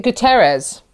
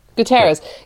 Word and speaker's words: Guterres: Is it Guterres? – Yes. – Guterres.